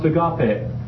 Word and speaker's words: agape: See, in the Greek it's pronounced agape, just like that.